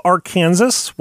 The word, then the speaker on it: Arkansas: And if you're wondering why it's pronounced Arkansas instead of Arkansas, well, that's what we're here to explain.